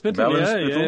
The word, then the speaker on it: Ballinspittle: Finbar Wright, you grew up in a large family in, I don't know how you pronounce it, Ballinspittle? – Ballinspittle. It was famous a few years ago for its moving statue, believe it or not.